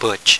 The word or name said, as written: butch